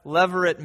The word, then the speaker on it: levirate: And the second institution that we need to know something about is that of levirate marriage. Levirate marriage. Levirate spelled L-E-V-I-R-A-T-E, if you're taking notes this morning.